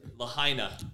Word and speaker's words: Lahaina: Lahaina.